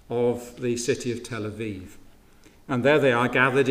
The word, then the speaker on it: Aviv: The month Aviv, we get it in scripture as Abib, but you pronounce the B as a V, Aviv.